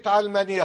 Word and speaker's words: علمانية: أولا كلمة علمانية قد تكون بفتح العين وهذا هو الأصوب وقد تنطق بكسر العين